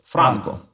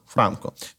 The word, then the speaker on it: Франко: Там ще є один цікавий нюанс щодо прізвища щодо наголосу самого. І цей наголос теж симптоматичний, тому що е, маємо свідчення, що і у франковій стороні, в його краї, е, і саме це прізвище в Європі наголошувалося з наголосом на першому складі, тобто Франко.